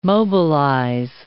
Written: mobilize

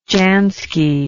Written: jansky

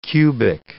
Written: cubic

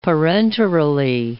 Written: parenterally

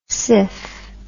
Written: siph

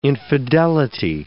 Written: infidelity

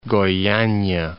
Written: Goyania

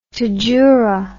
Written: tadjoura

